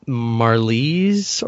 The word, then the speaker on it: Marlize: but I actually felt like Marlies or whatever, however you would pronounce it. – That's how you say it.